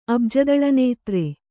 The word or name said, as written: ಅಬ್ಜದಳನೇತ್ರೆ